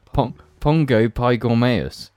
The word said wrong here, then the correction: Pongo pygmaeus: I think it's pronounced Pongo Pygmaeus.